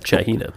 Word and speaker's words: Chagina: It's spelled C-H-A-G-I-N-A, but the G pronounced like an H, so it comes out more like a C-H-A-H-E-N-A kind of thing, Chahina.